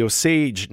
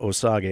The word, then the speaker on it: Osage: Martin Scorsese's film explores Oklahoma in the 1920s as the Osage Nation and not Osage, as I used to pronounce it. So good to have that corrected.